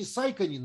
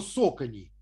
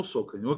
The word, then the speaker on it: Saucony: Ну, я почему-то всегда думал, что они Saucony называются. Но вот, ну, он, да. Вот этот чувак, он коллекционер, и я думаю, что он знает, как правильно произносить. Он сказал, что Socony. – А, ну Socony, окей.